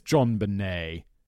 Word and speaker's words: JonBenét: Oh, it is JonBenét. JonBenét. Sounds very French, doesn't it? Everyone has already been in the comments commenting like, Simon, you didn't even look up the pronunciation. I just assumed it was JonBenét because it looks like JonBenét, doesn't it?